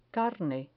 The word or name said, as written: carne